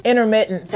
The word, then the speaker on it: intermittent: What I've realized too is me doing this intermediate. How do you say it again? – Intermittent.